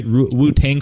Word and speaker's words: Wu-Tang: And it was created actually from a Wu-Tang, am I saying that right? Wu-Tang Clan? – Yep. Wu-Tang.